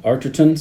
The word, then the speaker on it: Arterton: Gemma Arterton. Arterton, sorry. – He listened to her say her name because he knew he was saying it wrong. – Yeah, I can't say any of these names. – He can't pronounce that R. That's right after the A.